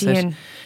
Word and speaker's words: Shein: Yeah, like I have far less of an issue with Pennies than I have with things like Shein or Shine. I don't know how to pronounce Shein.